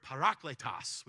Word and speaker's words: parakletos: That's the Greek word parakletos, which is a fun one to say because you get to roll your R a little bit, right?